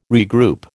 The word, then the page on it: regroup: American English